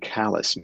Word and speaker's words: Calais: So I am struck by, well, on Sunday I was in Calais, Maine. I'm sorry for the pronunciation, but that's how we say it.